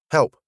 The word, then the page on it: help: British English